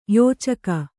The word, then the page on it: ಯೋಚಕಿ: Kannada